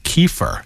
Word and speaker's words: kefir: And I heard that Americans pronounce it kefir, like Kiefer Sutherland. Have you ever tried kefir? – Yes, I have tried kefir.